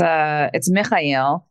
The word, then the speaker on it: Mechael: It's Mechael. In Arabic. And when my dad immigrated to America, he spelled it M-E-C-H-A-E-L and then decided to pronounce it Mechael to make it easier for people to say.